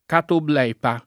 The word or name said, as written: catoblepa